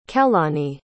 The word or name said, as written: kehlani